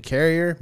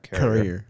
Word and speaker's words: courier: Episode 1, The Courier Meets Sole Survivor. This is metaphorical for me. – Yes, it is. Although it's pronounced courier, not carrier.